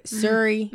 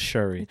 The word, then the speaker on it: Shuri: Suri. – Mm-hmm. – First of all, it's Shuri.